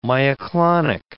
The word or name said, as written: myoclonic